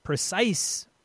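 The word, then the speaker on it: précis: Number four, wrote a precise of history.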